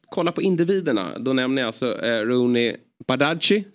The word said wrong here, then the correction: Bardghji: som eh, Olof Lund eh, säger, uttalar hans efternamn. – Bardghji heter han. – Bardghji heter han då tydligen.